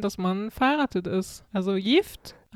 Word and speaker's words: Gift: Dann haben wir noch ein schönes Wortpaar, das man irgendwie auch äh, lustig interpretieren kann, denn es gibt ja das schwedische Wort Gift, G-I-F-T auf Schwedisch allerdings Gift ausgesprochen.